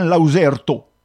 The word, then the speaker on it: lauzerta: Je ne vois pas trop le lien entre luzerp et lauzerto, parce que la véritable forme, c'est l'occitan lauzerto. Je prononce en essayant que vous entendiez le son. Le Z, c'est une espèce de ZJ à la portugaise.